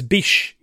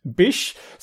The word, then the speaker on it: Bysshe: So apparently it's Bysshe. – Yeah, no, it looks very strange. Bysshe. So B-Y-S-S-H-E.